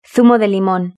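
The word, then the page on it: zumo de limon: Spain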